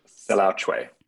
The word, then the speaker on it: Selaocoe: Selaocoe? – Selaocoe.